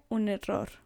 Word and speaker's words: error: Fue un error. – Fue un error. So fue means it was, and you'll hear that all the time. Un error. And don't forget to roll your R's.